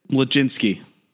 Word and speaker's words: Mlodzinski: Carmen Mazinski, is that even how you say it? Can you correct me there? – Mlodzinski. – Mlodzinski? – Mlodzinski. You have to mold the M, okay, and the L together.